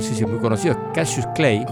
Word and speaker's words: Cautious Clay: Se escribe Cautious Clay, se pronuncia igual Cautious Clay.